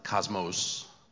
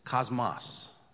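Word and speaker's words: kosmos: If you grew up on Buck Rogers, you'd pronounce it cosmos. But it is cosmos with a K.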